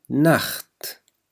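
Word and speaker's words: Nacht: دي نخت، دي نخت، نخت. التسي والها هون تلفظ مثل حرف الخاء في اللغة العربية.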